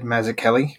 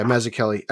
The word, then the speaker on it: Mazzucchelli: David Mazzucchelli. – Yeah, Mazzucchelli. It looks very similar to Mazzucchelli's Gordon.